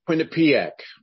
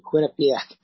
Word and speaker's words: Quinnipiac: I can never pronounce this. The Quinnipiac cult poll from last week? – Quinnipiac. – Yeah, thank you. Quinnipiac.